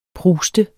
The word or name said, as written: pruste